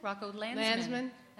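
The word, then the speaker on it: Rocco Landesman: Did I say it right? – Rocco Landsman. – Somebody else has the name Landerman. That's what got in my head. Yes, Landsman.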